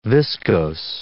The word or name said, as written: viscose